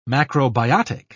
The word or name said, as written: macrobiotic